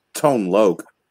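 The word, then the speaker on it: Tone Lōc: Then I'll just go with Courtney Cox was in Ace Ventura Pet Detective with, I was gonna say Tone Locke. But I won't go that far. I'll just say. – And it's Tone Loke.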